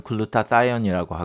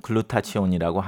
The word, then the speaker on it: Glutathione: 영어 발음으로는 글루타다이온이라고 하고 한글로는 글루타치온이라고 하는 이 물질은 우리 몸에서 직접 만드는 항산화 물질 중에 가장 많고 중요한 물질입니다.